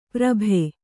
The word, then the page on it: ಪುರ್ಬು: kannada